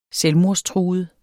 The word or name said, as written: selvmordstruet